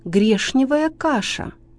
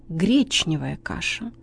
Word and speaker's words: гречневая: Например, «коришневый» – ошибка, правильно «коричневый», «молочный» – ошибка, правильно «молочный», «грешневая каша» – ошибка, правильно, гречневая каша.